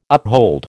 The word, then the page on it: uphold: American English